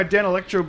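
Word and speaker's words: Danelectro: I'm going to take my Dan Electro bass.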